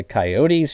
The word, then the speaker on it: Coyote: East of the Mississippi River, it's Coyote. I will not hear any other argument. Coyote is if you're trying to sound like a cowboy.